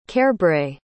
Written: cairbre

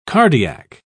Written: cardiac

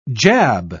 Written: jab

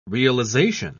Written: realization

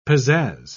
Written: pizazz

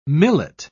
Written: millet

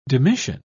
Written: demission

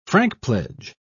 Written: frankpledge

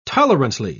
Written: tolerantly

